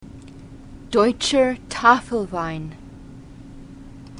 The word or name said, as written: deutscher tafelwein